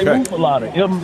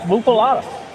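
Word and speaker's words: muffuletta: So you don't say a muffaletta, muffaletta. It is a muffaletta. M-O-O-F-A-L-A-D-A. – Muffaletta. – How you pronounce it? So muffaletta. – Going forward, guys, it's muffaletta. – Okay, thank you.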